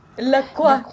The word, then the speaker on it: La Croix: La Croix.